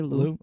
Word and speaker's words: Liu: Am I saying that right? L-I-U. Is that Lou? – I say Lou.